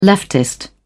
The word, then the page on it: leftist: British English